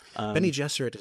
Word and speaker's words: Bene Gesserit: the Bene Gesserit. – Bene Gesserit is the way the movies have done it, and the audiobook.